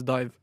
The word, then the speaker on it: DIIV: Eh, det skrives D-I-I-V, ikke sant? I all caps. Men det uttales Dive.